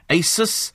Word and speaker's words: Asus: Today, I hope I'm going to pronounce this correctly, you could get your hands on an Asus VivoBook laptop. Did I get that right? Yeah, producer went, big thumbs up on that one.